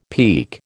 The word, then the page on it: peek: American English